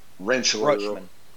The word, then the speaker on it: Rutschman: Uh, uh Rutsch- Rutschman. – Adley Rutschman.